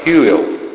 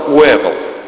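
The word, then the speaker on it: Whewell: Вообще, если его произносить правильно, это будет примерно так Хьюэлл. Уильям Хьюэлл. Если его произнести по-английски. Но с этим на русский язык его стали переводить, он жил в 19 веке, еще в 19 веке, и с таким, конечно, не справились. Поэтому по-русски он писался Уэвелл. Это не имеет никакого отношения к тому, как он звучит.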